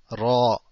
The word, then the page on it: ر: Ra